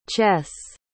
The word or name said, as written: ches